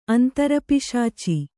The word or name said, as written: ಅಂತರಪಿಶಾಚಿ